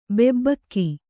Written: ಬೊಬ್ಬಿಕ್ಕು

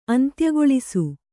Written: ಅಂತ್ಯಗೊಳಿಸು

